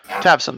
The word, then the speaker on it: Tavson: Tavis? That sound right? Am I pronouncing that right? Tavson. There we go.